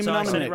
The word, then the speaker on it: mnemonic: Johnny Mnemonic. – Right, mnemonic. – Johnny Mnemonic. – It's basically just the M is silent.